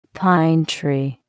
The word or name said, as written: pine tree